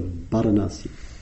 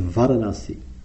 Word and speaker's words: Bārāṇasī: So he uses his psychic powers and he discovers that they're staying at a place called Isipatana, north of Bārāṇasī. what is now called, sometimes pronounced Varanasi.